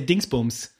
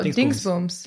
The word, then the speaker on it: Dingsbums: It's funny because uh, I googled magic and then yeah, and I think it's a, uh, in German we say Dingsbums. Dingsbums. – Dingsbums. Wait, how do you spell that? Oh, uh, so it's D I N G S and then B U M S.